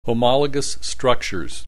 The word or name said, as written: homologous structures